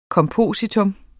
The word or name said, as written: kompositum